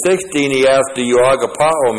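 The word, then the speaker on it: agapaō: In verses 15 and 16, Jesus asks Peter, do you agapao me? A-G-A-P-A long O. Do you agapao me?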